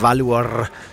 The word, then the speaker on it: Valur: Hvordan er det med, du udtaler det? – Valur. – Valur. Valur fra Reykjavik. Det er nemlig rigtigt.